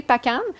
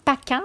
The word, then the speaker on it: Pakan: S'il y a des gens qui, euh, savent vraiment PACAN, je présume que ça se prononce comme ça, P-A-K-A-N.